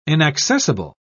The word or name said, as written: inaccessible